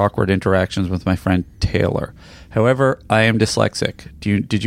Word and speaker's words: Taylor: I have a terrible time with the lady's name Taylor. He's talking about my pronunciation of... – Bow. – He says Tyler, which is a man's name.